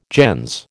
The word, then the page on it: gens: American English